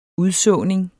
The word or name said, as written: udsåning